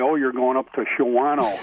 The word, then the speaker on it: Shawano: It's, and people put the A, they pronounce the A and they shouldn't. – Right. – Shawano. That's the Shawano instead of Shawano.